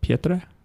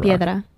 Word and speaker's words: piedra: And in Spanish, it's Pietra? No? Petra. Nope. – I don't know. I've never played it in Spanish. – How do you say rock? I'm just trying to say rock. – Piedra.